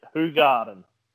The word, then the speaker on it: Hoegaarden: Yeah, so we are pronouncing it wrong. it's on their website. It's supposed to pronounce it Hoegaarden. So we've been pronouncing it wrong this whole time. I think you actually you said it right at one point, but then you went back to saying it the Australian derelict way. – The way that it's written, not the way that it's said.